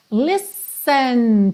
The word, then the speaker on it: listen: É só stop, look, yes, listen to your heart. Lembra que esse listen, você pronuncia como se fossem dois S's, tá? Esquece esse T aí.